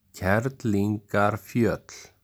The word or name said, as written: Kerlingarfjöll